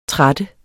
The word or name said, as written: tratte